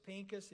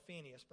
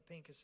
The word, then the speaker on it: Pinchas: So it's Pinchas. If you want to know who I'm talking about, it's what I used to call Phineas, but I think Pinchas is the right pronunciation.